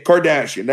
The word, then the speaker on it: Kardashian: Kardashian, that's how you say it.